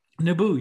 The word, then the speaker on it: Naboo: He helps them make their way to the capital city of, what is it, Naboo? – Naboo, yes. – Did I pronounce that correctly? Naboo? – Yeah.